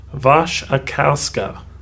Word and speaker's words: Wasikowska: It's Wasikowska. – Yeah, I think that's a Polish name, but you don't say W-Was, you say Vas. – Wasikowska, which I think I've mispronounced on this podcast before.